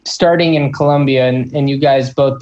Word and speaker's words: Colombia: did i pronounce that closer this time colombia perfect, perfect.